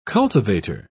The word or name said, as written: cultivator